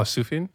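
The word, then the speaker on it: Sufjan: Sutphin. Am I saying that right? – Oh, Sufyan.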